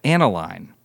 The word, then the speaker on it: Annaline: Annaline. – Rhymes with Caroline.